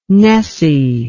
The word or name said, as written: nessie